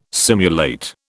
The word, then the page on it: simulate: British English